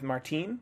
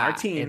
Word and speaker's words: Martine: And I like this mirroring with Martine. Not Martin. How it's spelled. – Yeah, Martine. – It's Martine.